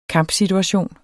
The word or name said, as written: kampsituation